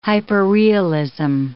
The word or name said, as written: hyperrealism